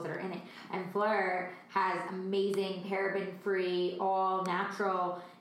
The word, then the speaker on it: Phlur: The first one I'm going to talk about is Fleur, P-H-L-U-R, but it's pronounced Fleur, like the French word for flower, which is something I can almost pronounce better than Italian, but not really by much.